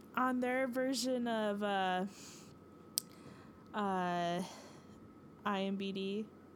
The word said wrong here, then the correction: IMDB: or I always feel like I say that wrong. Yeah, no, IMDB, excuse me.